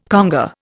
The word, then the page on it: conga: Indian